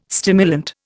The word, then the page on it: stimulant: Indian